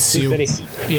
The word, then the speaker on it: Sioux City: How do you say that? Sioux. – I think it's Sioux City. Yeah, Sioux City.